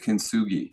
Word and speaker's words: Kintsugi: Kintsugi. – Kintsugi.